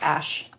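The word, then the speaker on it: Æ: A-E-S... – Ash. – 71? Ash. Yeah. Okay. That's fine. – Yeah. A-E is that sort of symbol where you see the two of them fused together is, I think, pronounced Ash.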